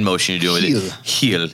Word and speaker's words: Gil: And along those lines, also Carlos Gil, who I've been informed that this is how you pronounce his name. – Oh, it's a tricky one, but it sounds sexy when it rolls off your tongue. – Heel.